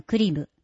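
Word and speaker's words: 栗夢: ただ名前はグリムではなくクリム、栗に夢と書いてクリム、童話は道に話と書いて童話です。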